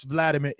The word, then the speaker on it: Vladimir: Listen, Steven, if Vladimir, oh, excuse me, people are so mad that I'm pronouncing it with the W, it's Vladimir.